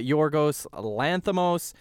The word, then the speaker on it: Yorgos Lanthimos: We got Yorgos Lanthimos.